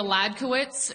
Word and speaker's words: Hledkowicz: Yeah, my name is Julia Ladkowitz. It's spelled H-L-E-D-K-O-W-I-C-Z.